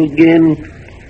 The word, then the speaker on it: again: So soon I'll be right back with you again. Oh, wait, hold on. That again is supposed to rhyme with train.